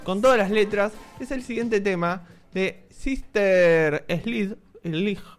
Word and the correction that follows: Sister Sledge: Ah, pero creo que es Sister Sledge, que sería como el equipo de las hermanas, el conjunto de hermanas.